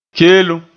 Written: keelem

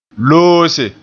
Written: loose

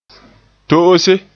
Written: to'ose

